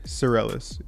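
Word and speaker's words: Psarellas: Sorellis.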